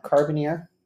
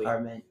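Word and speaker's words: Carménère: I looked it up. Carbonier? – No B. – No B. – Carmenier. – Carmenier. – Like the girl Carmen, and then Nier.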